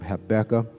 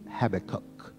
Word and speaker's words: Habakkuk: My seminary professor pronounces Habakkuk. Habakkuk.